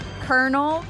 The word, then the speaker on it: colonel: I think I'm going to go with kernel, because it's actually spelled like colonel, but it's kernel, like a popcorn kernel.